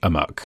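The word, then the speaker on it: amok: So I think probably originally it was pronounced amuk, but I would not be surprised if there were parts of the world where it rhymes with Spock.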